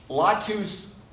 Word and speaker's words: Latus: Latus with a long A.